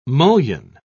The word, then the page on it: mullion: British English